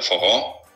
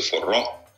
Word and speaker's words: forró: Хотя, а, если мы говорим о фуро, и как в Бразилии, про фуро, но я произношу его, соответственно, в русской транскрипции «форро».